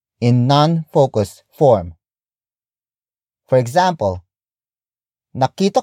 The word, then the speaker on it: nakita: The first word is nakita. Nakita. Nakita. Nakita is spelled as N-A-K-I-T-A.